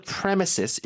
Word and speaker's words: premises: 네, 그래서 on the premises, 읽을 때 잘 읽어야 됩니다. Premises라고 읽으면 안 되고, no. – premises, 한번 읽어주세요.